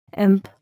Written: imp

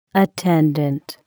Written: attendant

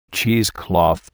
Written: cheesecloth

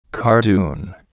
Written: cardoon

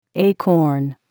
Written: acorn

